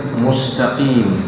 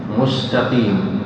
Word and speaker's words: مستقيم: قال: وسين مستقيم، أمرك الآن بترقيق حرف السين من كلمة مستقيم، مستقيم،